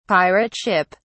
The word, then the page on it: pirate ship: British English